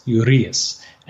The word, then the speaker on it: Urias: But I have flubbed the pronunciation of Ramon Urias for the entire season, and now I have it right. It's Urias.